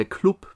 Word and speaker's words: Club: Natürlich bekannt als der Club, beziehungsweise der Glub, ne, ist ja Franken, ist ja alles ein bisschen, bisschen weicher in der Aussprache, die Glubberer.